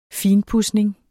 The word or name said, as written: finpudsning